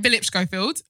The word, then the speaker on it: Philip Schofield: Philip Schofield. Did I pronounce his name right? – Yeah.